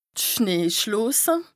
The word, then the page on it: d' Schneeschloose: Bas-Rhin; Strasbourg